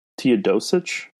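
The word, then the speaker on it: Teodosić: I actually also really like Teodosic, if I'm saying that right.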